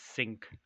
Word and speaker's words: Cinq: I did also try, they had a Chimay night, so they had tapped the Chimay 500, which is spelled like CINQ and then the word cents, like dollars and cents, but apparently it's supposed to be pronounced sink, 500.